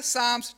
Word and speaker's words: Psalms: So, it's pronounced Psalms, but it's got a silent P at the start.